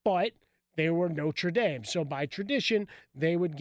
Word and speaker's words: Notre Dame: Got to say Notre Dame.